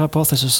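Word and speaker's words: Hypotheses: Ich weiß immer nicht, wie ich es ausspreche. Hypothesis oder? – Ja, würde Hypothesis sagen, ja.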